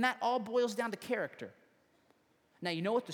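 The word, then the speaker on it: Character (χαρακτήρ): It's this Greek word that's pronounced like this. Character.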